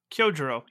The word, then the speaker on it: Kyojuro: Kirojuro? Kyojuro. Yep. Kyojuro.